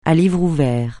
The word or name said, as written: à livre ouvert